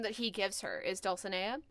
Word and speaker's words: Dulcinea: Dulcinea, or rather, okay, so Dulcinea is the way that Bismarck says it. It should actually be pronounced Dulcinea or Dulcinea, because it's a Spanish word.